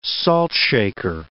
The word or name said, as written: saltshaker